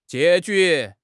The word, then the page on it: 结局: HSK6